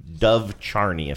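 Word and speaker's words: Dov Charney: 2009, and this involves American Apparel / Dove Charney, if I'm saying his name right.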